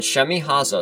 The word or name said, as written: Shemihazah